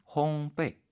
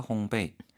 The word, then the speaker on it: 烘焙: 이 글자 자체가 베이가 연상이 되는 언뜻 보면 그러나 정확한 발음은 烘焙 이렇게. 베이. 그래서 빵을 굽다 베이킹하다 烘焙.